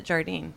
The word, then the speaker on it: Jardine: That's like how there's a Beach Boy, Al Jardine. – Ooh. – And he pronounces it Jardine.